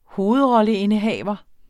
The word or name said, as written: hovedrolleindehaver